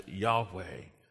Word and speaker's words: Yahweh: The Hebrew is the tetragrammaton. Uh, we pronounce it as Yahweh.